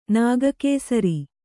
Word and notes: ನಾಗಕೇಸರ: nāga kēsara

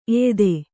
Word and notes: ಎಯ್ದು: eydu

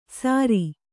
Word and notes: ಸರಿ: Kannada

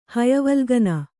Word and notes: ಹಯವಲ್ಗನ: Kannada

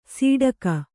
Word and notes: ಸೀಡಕ: sīḍaka

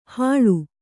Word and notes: ಹಳು: haḷu

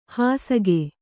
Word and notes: ಹುಸಿಕ: Kannada

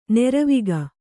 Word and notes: ನೀರ್ವಕ್ಕಿ: nīrvakki

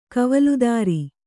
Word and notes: ಕವಲುದಾರಿ: kavaludāri; Kannada

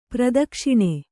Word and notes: ಪ್ರಾದಕ್ಷಿಣ: prādakṣiṇa

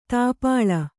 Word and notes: ದೀಪಾಳಿ: kannada

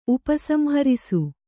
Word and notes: ಉಪಸಂಹರಿಸು: Kannada

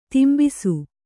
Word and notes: ದಂಬಿಸು: dambisu; kannada

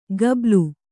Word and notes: ಗಬ್ಬಲು: Kannada

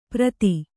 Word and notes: ಪ್ರತಿ: prati

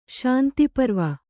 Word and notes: ಶಾಂತಿಪರ್ವ: śanti parva

